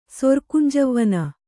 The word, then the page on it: ಸೊರ್ಕುಂಜವ್ವನೆ: Kannada